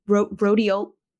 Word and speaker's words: rhodiola: It's the R one, rhodiola.